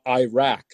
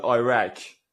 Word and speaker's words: Iraq: what really got under her skin was all the Americans saying Iraq instead of Iraq, Iraq. So, Iraq. You say Iraq. – Yeah, we tend to say Iraq. Iraq. Yeah, Iraq and Iran, like Iran for the ball.